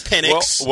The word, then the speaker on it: Penix: It's been in the pronunciation guide. It's Penix.